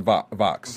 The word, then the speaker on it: Vox: And then you went on, is it VOX or is it pronounced Vox? – Vox.